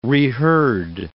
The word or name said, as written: reheard